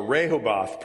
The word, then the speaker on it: Rehoboth: Pittsburghers love Rehoboth, or Rehoboth probably is how you say it, or closer in the Hebrew.